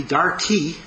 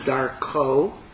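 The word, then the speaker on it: darko (דרכו): As someone said, unfortunately, some people pronounce it like Glitziano Yid. They say, chanoch l'nar al pi darki, meaning train the child according to my way, but we should pronounce it chanoch l'nar al pi darko, that the child has a way.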